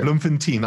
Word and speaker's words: Bloemfontein: you did the most Joburg thing, Bloemfontein.